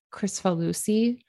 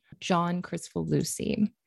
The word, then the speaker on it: Kricfalusi: And I think you pronounce his name Kricfalusi, John Kricfalusi.